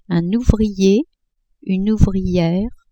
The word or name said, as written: ouvrier-ouvriere